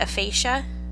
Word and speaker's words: aphasia: In March, his family announced he had been diagnosed with, how do you say that, aphasia? – Yeah.